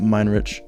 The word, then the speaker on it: Minarich: Minarich. Actually, the A's silent, but I've just given up on that, so. – Wait, so how's it supposed to be pronounced? – Minarich.